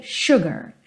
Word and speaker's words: sugar: E normalmente, se eu estivesse só falando essa palavra, seria sugar, sugar. Como nessa canção a gente tem na melodia pam, pam, então a gente tem sugar, sugar. Quase que as duas sílabas com o mesmo peso, tá? Mas isso é uma situação dessa canção, dessa melodia, normalmente sugar, sugar.